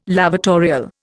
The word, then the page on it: lavatorial: Indian